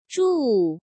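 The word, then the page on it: Zhu Wu: Chinese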